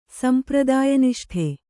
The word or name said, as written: ಸಂಪ್ರದಾಯನಿಷ್ಠೆ